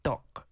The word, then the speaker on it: 떡: 떡, it's got a 상디귿 there, so be careful with the pronunciation. It has to be strong.